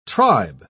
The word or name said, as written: tribe